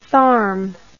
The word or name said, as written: tharm